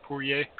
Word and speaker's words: Poirier: I believe his last name is pronounced Poirier.